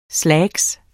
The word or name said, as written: slacks